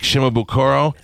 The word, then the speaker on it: Shimabukuro: This is Jake Shimabukuro.